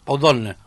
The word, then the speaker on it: Pau Donés: Pau Donés. – Pau Donés. – Perdón, la E casi que se pronuncia.